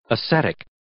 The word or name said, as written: ascetic